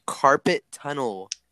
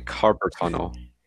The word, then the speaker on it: carpal tunnel: Carpet tunnel. – Oh, there it is. Carpet. The famous bit. – I say carpal tunnel. – Carpal tunnel. – Is that not pronounced correctly? – No, I'm asking, how do you pronounce it? – That's the way I pronounce it.